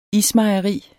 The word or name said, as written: ISMEJERIER